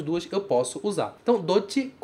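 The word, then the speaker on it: Dochi (どっち): Cuidado com a pronúncia do dochi. Porque ele tem um tsu pequeno. Logo tem uma pausa. Dochi. Dochi.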